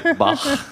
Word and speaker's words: Bach: But I feel like your musical background, you were able to reference Bach and say Bach properly. – Oh, it's because my mom's German and I took German lessons growing up. – Okay. – I can't pronounce it the other way. It feels really bad.